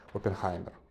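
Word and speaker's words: Оппенгеймер: Я на выходных сходил на Open Опенгеймер. Или, как здесь в Чехии говорят, Опенхаймер. Потому что, в общем-то, его фамилию правильно все-таки, наверное, произносить на немецкий лад.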